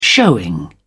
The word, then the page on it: showing: British English